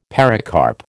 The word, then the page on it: pericarp: American English